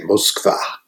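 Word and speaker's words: Moskva (Москва): And so when I got back, people said, well, do they say Moscow or Moscow? And I said, they say Moskva.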